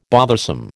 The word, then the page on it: bothersome: American English